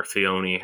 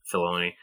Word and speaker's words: Filoni: You mentioned Will, Dave Filoni or Fioni, however you pronounce him. Filoni.